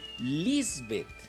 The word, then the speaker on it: Lisbeth: Y entonces yo, me acuerdo, es con acento en la I, Lisbeth.